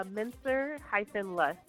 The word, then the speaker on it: Mincer-Lust: And Jasmine, please say your last name again, for I don't want to mess it up. – Yes, it's Mincer-Lust. L-U-S-T.